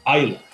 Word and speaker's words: Islay: As it's spelled, you know, Islay, but that's not how it's pronounced. It's pronounced Islay, the Islay region of Scotland.